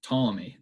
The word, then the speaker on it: Ptolemy: Ptolemy, that's a tough one. It's a silent P at the beginning. P-T-O-L-E-M-Y.